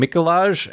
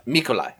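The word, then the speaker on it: Mikołaj: His name is pronounced like Nikolai, like it rhymes with Nikolai. – Oh, okay. So Mikolai.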